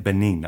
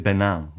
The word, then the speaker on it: Benin: Okay, so you're pronouncing it Benin. Okay. here in Japan, they call it Benin, which is probably the some French pronunciation or something.